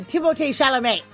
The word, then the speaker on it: Chalamet: Timothée Chalamet. – That's right. Also, that is how his name is actually pronounced.